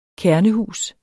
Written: kernehus

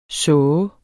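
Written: såh

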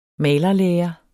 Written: malerlære

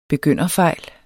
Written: begynderfejl

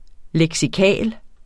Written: leksikalsk

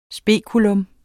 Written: spekulum

